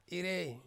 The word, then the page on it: irei: Ansus